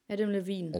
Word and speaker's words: Adam Levine: Levine. Wie wird er ausgesprochen? – Adam Levine. – Adam Levine.